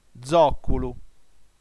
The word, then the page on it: zòculu: dzò.cu.lu; ['dzɔk:ulu]